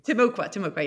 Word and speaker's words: Timucua: So in the first few paragraphs of the book, you introduce the story of Timucua Eve. Am I pronouncing that correctly? – Timucua, yeah.